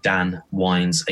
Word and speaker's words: Dan Wynes: And you can find me on all forms of social media, Instagram, Facebook, Twitter, YouTube, with the username Dan Wynes. Again, that's spelled W-Y-N-E-S.